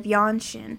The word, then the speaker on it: Yang Xin: We will be discussing two true crime cases from Blacksburg The first case that we will be discussing is the death of Yanshin.